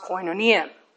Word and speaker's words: koinonia: It is in the ancient Greek koine, and the word is pronounced koinonia.